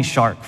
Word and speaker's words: shark: Because they call me shark for some reason. None of us remember why they call me shark. It rhymes with Mark.